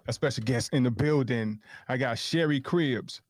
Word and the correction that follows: Cribb: Did I say your name right? – Cribb. No S.